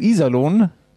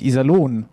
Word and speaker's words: Iserlohn: Sagst du Iserlohn und mit der Betonung auf I, dann rasten die schon aus im Internet. weil es heißt nämlich Iserlohn und nicht Iserlohn.